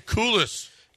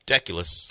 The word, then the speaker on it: Deculus: But the Texans started to cool us. – Deculus.